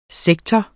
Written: sektor

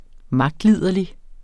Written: magtliderlig

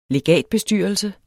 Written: legatbestyrelse

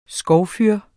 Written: skovfyr

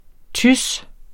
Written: tys

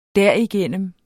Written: derigennem

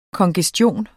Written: kongestion

